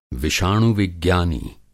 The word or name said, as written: विषाणु-विज्ञानी